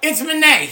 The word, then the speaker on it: Manet: Edouard Manet. – Yeah, but there's a thingy on top, so it's not Ed. It's listen, it's Manet.